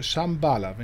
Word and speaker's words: Shambhala: Wir hören jetzt Katja Alemann selbst singen mit dem Stück Shambhala, wenn ich das jetzt richtig ausgesprochen habe.